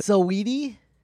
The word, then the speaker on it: Saweetie: I saw Saweetie. Is that how you say it? – Saweetie? Yeah, you said it right.